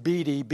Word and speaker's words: Bede: The Catholic saint, I think it's pronounced Bede, B-E-D-E, died in 735 A.D.